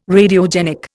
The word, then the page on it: Radiogenic: Indian